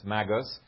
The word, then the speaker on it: magus: unless it's singular, it's a hard G then, it's magus.